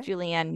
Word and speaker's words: Julieann: So on Instagram, I'm at Miss Julieann Music.